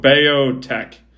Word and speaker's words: Beothuk: It's, I'm calling it Biotech. But it's spelled... No. It's spelled B-E-O-T-H-U-K.